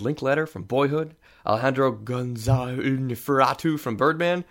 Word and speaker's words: Iñárritu: It's got the tilde on it, so it's Iñárritu.